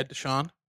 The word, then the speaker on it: Deshaun: Yeah, Deshaun.